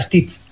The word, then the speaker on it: Stith: Baina patuan, pronunciazioa da Stitz. – Ados. – Orduen, Stitz, STITH, baina bere motea da kin Stitz, STITT.